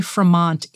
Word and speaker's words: Fremont: John C. Fremont, it rhymes with Vermont.